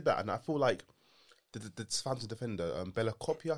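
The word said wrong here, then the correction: Bella-Kotchap: I think is how you pronounce Bella Kochap.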